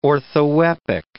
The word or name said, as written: orthoepic